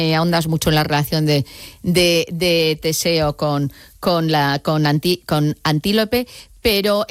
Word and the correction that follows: Antíope: me gustaría lo he dicho mal el nombre, es Antíope.